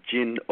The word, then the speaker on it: Jin: And it's actually Jin with an N, not with an M.